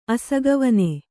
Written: ಅಸಗವನೆ

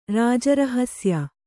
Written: ರಾಜರಹಸ್ಯ

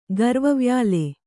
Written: ಗರ್ವವ್ಯಾಲೆ

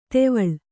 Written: ಧಾವಳಿ

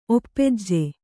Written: ಒಪ್ಪೆಜ್ಜೆ

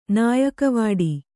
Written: ನಾಯಕವಾಡಿ

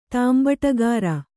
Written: ತಾಂಬಟಗಾರ

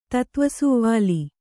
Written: ತತ್ತ್ವಸುವಾಲೆ

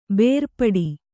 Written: ಬೇರ್ಪಡು

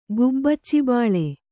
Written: ಗುಬ್ಬಚ್ಚಿಬಾಳೆ

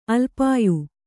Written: ಅಲ್ಪಾಯು

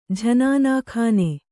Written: ಜನಾನಖಾನೆ

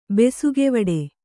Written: ಬೆಸುಗೆವಡೆ

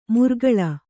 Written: ಮುರ್ಗಳ

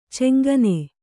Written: ಛಂಗನೆ

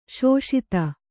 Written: ಸಾಸದ

